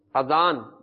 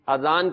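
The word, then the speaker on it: اذان: آذان درست ہے, یعنی الف کے اوپر کھڑا زبر نہیں ہے بلکہ صرف زبر ہے آذان, آذان کہنا چاہیے, درست تلفظ یہ ہے, تو آج سے اس تلفظ کو درست کر لیجئے.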